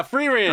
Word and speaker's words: Frieren: I am completely caught up on, I don't know how to fucking pronounce it, Frieren? – Yeah, Frieren.